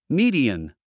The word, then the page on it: median: míːdiən; ミィディアン